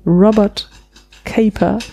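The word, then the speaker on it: Robert Capa: Das hast du herzerliebt deutsch ausgesprochen, den Namen. – Robert Caper. – Oder Kapper. – Ja, ich dachte, das war ein Italiener. – Roberto Kappa. – Stimmt, der würde Roberto heißen.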